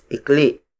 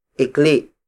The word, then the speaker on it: ikli: Mesa, the Tagalog word for table, is spelled as M-E-S-A. Again, that's haba and mahaba. The opposite of haba is ikli. Ikli. Ikli. Ikli is spelled as I-K-L-I. Note the glottal stop at the end. Ikli, not ikli.